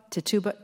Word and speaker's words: Tituba: I want you to tell us the story of, how do you pronounce, Tituba? Tituba? – Mm-hmm.